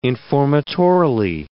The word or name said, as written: informatorily